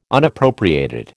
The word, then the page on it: Unappropriated: American English